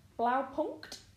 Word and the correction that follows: Blaupunkt: I don't think you pronounced the T. It was Blaupunkt. – Blaupunkt.